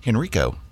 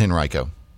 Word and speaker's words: Henrico: Or as Kaleo would have said yesterday, Henrico. But oh. – I don't think that's right in Virginia. Apparently that's the Chicago area pronunciation of the word. We're going to stick with Henrico.